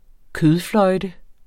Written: kødfløjte

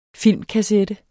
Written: filmkassette

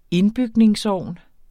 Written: indbygningsovn

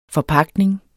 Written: forpagtning

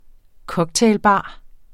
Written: cocktailbar

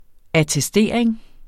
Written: attestering

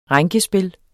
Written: rænkespil

